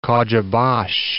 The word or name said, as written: Kocabas